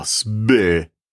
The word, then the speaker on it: Sber: I don't know how to pronounce this, S-B-E-R, SBER, SBER.